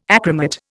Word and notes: achromat: Indian

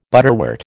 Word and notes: butterwort: American English

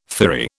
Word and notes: firry: British English

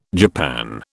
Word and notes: japan: British English

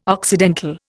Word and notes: occidental: Indian

